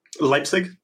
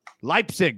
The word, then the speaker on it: Leipzig: Leipzig. – Leipzig, there we go.